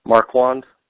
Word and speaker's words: Marquand: Marquand.